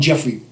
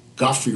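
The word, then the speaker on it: Geoffrey: We all call him Jeffrey Holder. – Right. – Godfrey Holder, this is his real pronunciation.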